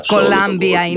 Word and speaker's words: Columbia: Columbia είναι η προφορά, η σωστή.